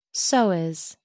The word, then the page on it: psoas: American English